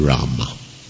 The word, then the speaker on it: Rama: But in terms of proper pronunciation, it should be there. Sanskrit. I mean, there's Bengali pronunciations and Hindi pronunciations, but Sanskrit, it would be Rama, Hare Rama.